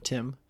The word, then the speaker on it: Timb: So I'm Timb. I spell my name with a silent B, like climber limb.